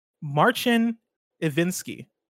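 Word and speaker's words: Marcin Iwiński: Big Bad Beluga writes in and says, CDPR head's name is pronounced Marcin Iwinski.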